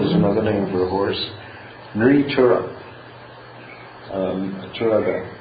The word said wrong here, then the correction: Turanga: I mean, Turanga.